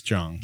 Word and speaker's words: Jeong: Is it pronounced Jeong? – I think it's Jeong.